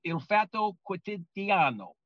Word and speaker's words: Il Fatto Quotidiano: Stefania Maurizi is an award-winning journalist, used to be with El Espresso, then she was with La Repubblica, and now she's with, and I hope I pronounced this right, and that is Il Fatto Quotidiano. – Yes. – Am I right, Stefania? – Yes, it's Il Fatto.